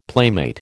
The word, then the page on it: playmate: American English